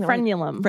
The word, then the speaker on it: frenulum: So the underside of their penis is called the frenum. Is that what I'm saying? – Frenulum. – Right? Frenulum. Thank you. I was saying it wrong.